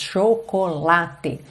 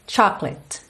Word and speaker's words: chocolate: Chocolate. Em inglês a gente vai ter chocolate. Chocolate. Ou seja, não se fala chocolate. Chocolate. Fica meio clate. Clate. Chocolate. A mesma coisa com covert.